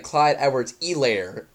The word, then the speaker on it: Clyde Edwards-Helaire: He's like, Clyde Edwards-Helaire. It was like kind of pissing. The H is silent. – It is silent. – He said it correctly.